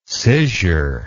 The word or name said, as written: scissure